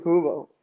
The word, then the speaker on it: Mukuba: And, you know, hats off to Andrew Mukuba.